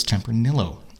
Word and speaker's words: Tempranillo: And the guide told us Tempranillo. – Like Amarillo.